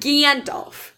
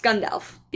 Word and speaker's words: Gandalf: It's not Gandalf. It's Gandalf. It's supposed to be, like, more the accent on the second syllable, but I suck at saying it, so, like, don't imitate what I'm trying to do.